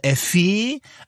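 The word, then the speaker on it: EFÉ: Das schreibt sich in Großbuchstaben E-F-E mit einem Ägü. Mhm. Man könnte jetzt denken, das wäre Efe, aber sie lässt verlauten auf ihrer eigenen Instagram-Seite, dass das Efe ausgesprochen wird.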